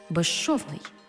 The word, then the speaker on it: безшовний: У словах безшовний і безшумний, свистячий з плюс шиплячий ш всередині слова разом вимовляємо як ж ши, безшовний, безшумний.